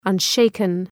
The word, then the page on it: unshaken: ʌn’ʃeıkən